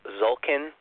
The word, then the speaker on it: Tzolkin: I've got to be careful that I don't pronounce it wrong, but I think you say it Zolkin, and I'll spell it for you, T-Z-O-L-K-I-N.